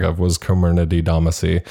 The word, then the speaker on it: Comirnaty: Comirnaty. It's spelled Comirnaty, Comirnaty, but it's Comirnaty.